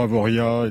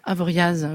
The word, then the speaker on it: Avoriaz: En général, on ne prononce pas le Z, effectivement. Même chose pour Avoria, etc. – Avoria, Avoriaz.